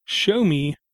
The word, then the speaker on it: Xiaomi: Show Me made basically something a little bit smaller than a Q-Box. Okay, it's called a Show Me, and that's spelled X-I-A-O-M-I. And they call it a Mi Box, M-I-B-O-X, Mini, FHD Media Player, MT8685, Android 4.4, quad-core, 1 gig of RAM, 4 gigabyte ROM, 1080p Bluetooth white.